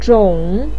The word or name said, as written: zhong3